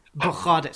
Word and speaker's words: Bogarde: I'll call it Winston Bogard comes on. – Well, don't mention Bogarde. – Bogarde, sorry. You're quite right. The Dutch pronunciation is...